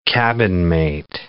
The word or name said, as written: cabinmate